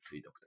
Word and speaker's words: 水毒: 水毒と言ったりとか、水の毒と書いて水毒と読みますけれども、とか、水の訴えと書いて水体っていうふうに言いますけれども、これが水の異常で、え最後にあるものですね。